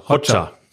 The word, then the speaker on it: Hoxha: Ja, äh, vielleicht hilfst du mir nur bei der Aussprache. Enver Hoxha? Hoxha? – Äh, Hoxha. – Hoxha. Hoxha.